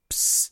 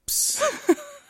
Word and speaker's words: Psst: Psst. I'll say that again. – Yes. – Chris... Psst. It's spelt how it sounds. P-S-S. Yes. – P-S-S. – The P is not silent.